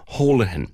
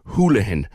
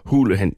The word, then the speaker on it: Holohan: Just on the subject of the Chief Medical Officer, before I go to the news, caller says, you pronounce his name Holohan. It is meant to be pronounced Houlihan, and he himself is on record as saying it annoys him that people can't get it right. It's a spelling variation of Houlihan.